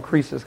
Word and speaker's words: Croesus: The real way the name is pronounced is Croesus. I've never heard it pronounced like that ever. Croesus. It's Croesus is the way that it's usually, or Croesus is the way that it's usually pronounced. It's the only way I've ever heard it. But in the pronunciation key that I have at home, they pronounce it Croesus, like a Z.